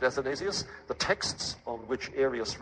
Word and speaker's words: Athanasius: You've probably heard his name, and I expect you can spell it, but just in case, let me spell it for you, A-T-H-A-N-A-S-I-U-S, Athanasius.